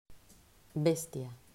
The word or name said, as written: Bestia